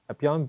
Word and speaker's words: 3PY0N: Sorry, So it'd just be Brian Yu on our station, and then I guess on like Twitter and stuff, it's Epion, but it's spelled 3PY0N.